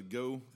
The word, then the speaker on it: Geaux: Oh, that was a Go Theater? – Yeah, Go. I haven't been there yet. It's spelled It's spelled French wise go, like G-E-A-U-X go.